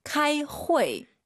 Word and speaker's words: 开会: 8시 15분에 회의를 시작합니다. 여러분, 회의하다, 회의를 시작하다 두 글자 제가 해볼게요. 발음 교정해 주세요. 카이 후이. – 하하하, 네. – 开会. – UI 사이에 에 넣으세요. 회. 자, 8시 15분에 회의한다.